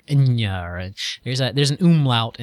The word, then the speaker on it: Enya: All right, I'm being told that it's Enya, so I guess you were saying it correctly. – Well, it is Enya, but I don't listen to it.